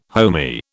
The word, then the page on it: homey: British English